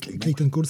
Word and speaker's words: Cowles: Je prononce ah très mal, c'est Clayton Cole, c'est juste le lettreur en fait.